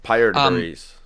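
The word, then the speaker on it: Piter De Vries: Whoever you, Peter DeVries or whatever it's pronounced. – Pyre DeVries.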